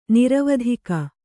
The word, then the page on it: ನೀರವತ್ತಿಗೆ: nīravattige